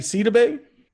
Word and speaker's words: Sidibe: Gabby Sidibe. – That's how you say her name. Bro. Thought it was Sididibe.